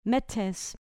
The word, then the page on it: metes: grec